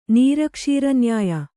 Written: ನೀರಕ್ಷೀರನ್ಯಾಯ

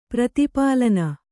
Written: ಪ್ರತಿಪಾಲನೆ

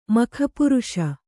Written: ಮಖಪುರುಷ

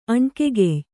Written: ಅಣ್ಕೆಗೆಯ್

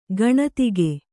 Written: ಗಣಿದಿಗ

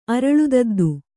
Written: ಅರಳುದದ್ದು

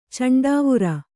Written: ಚಂಡಾವುರ

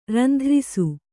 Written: ರಂಧ್ರಿಸು

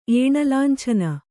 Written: ಏಣಲಾಂಛನ

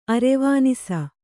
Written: ಅರೆವಾನಿಸ

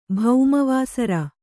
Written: ಭೌಮವಾಸರ

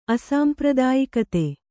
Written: ಅಸಾಂಪ್ರದಾಯಿಕತೆ

